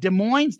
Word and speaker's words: Des Moines: Love both listening to Soundboard at Des Moines, the S is silent in 77, and your coverage of it.